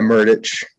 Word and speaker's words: Murdich: Yeah, Murdich.